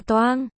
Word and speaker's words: Doang: Doang. Đọc là toang.